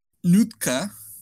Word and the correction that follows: Nduka: não sei como é que pronuncia Nduka. – isso. Nduka. E o Teut Miles.